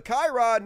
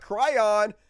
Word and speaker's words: Chiron: It's pronounced Chiron, not Cryon.